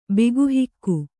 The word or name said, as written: ಬಿಗುಹಿಕ್ಕು